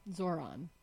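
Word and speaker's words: Zohran: So let's talk a little bit more about Zoran. Zoran. – Zoran. – Zoran. – Yes. – So the H is silent.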